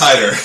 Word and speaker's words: Haider: Aspetto, you got it pretty close, but it's Haider.